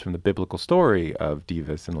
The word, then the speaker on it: Dives: Well, Dives is absolutely the British Latin pronunciation, but the Latin pronunciation, for whatever that's worth, I don't know. Those guys probably didn't know what they were saying, is supposedly Divus, I think.